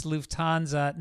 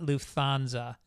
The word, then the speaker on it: Lufthansa: It's like the airline Lufthansa. It's Lufthansa, not Lufthansa.